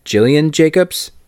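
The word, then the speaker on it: Gillian Jacobs: Obviously, I watched Mando, but then speaking of Community, I watched a documentary, technically, by Jillian Jacobs.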